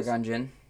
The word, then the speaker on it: Qui-Gon Jinn: Sorry, can you pronounce that name, please? – Qui-Gon Jinn? – No ding. – I did it.